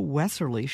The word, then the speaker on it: Wesserle: And joining us right now is Maria Wesserle.